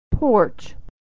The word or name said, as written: porch